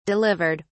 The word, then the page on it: delivered: British English